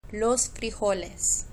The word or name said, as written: los frijoles